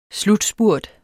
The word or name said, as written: slutspurt